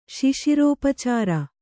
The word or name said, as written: ಶಿಶಿರೋಪಚಾರ